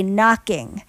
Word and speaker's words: Knockin': If you're keeping score at home, that song gets me 2 points because I have the hard K keep and then a silent K knocking.